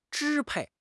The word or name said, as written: 支配